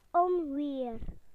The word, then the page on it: storm: British English